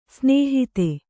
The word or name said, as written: ಸ್ನೇಹಿತ